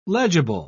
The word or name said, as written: legible